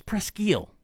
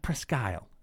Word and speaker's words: Presque Isle: It's Presque Isle, not Presque Isle. I knew it was Presque Isle.